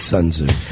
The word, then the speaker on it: Sun Tzu: It's Sun Tzu.